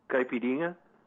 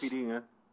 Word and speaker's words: Caipirinha: Caipirinha. – Caipirinha. Caipirinha. Am I close? – Caipirinha.